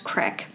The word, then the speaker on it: creek: I say creek. My boyfriend always makes fun of me because he says it's pronounced crick.